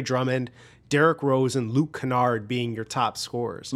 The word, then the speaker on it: Kennard: Kennard.